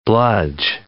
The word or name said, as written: bludge